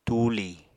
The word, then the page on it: tuuli: apatani